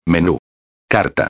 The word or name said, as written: menú, carta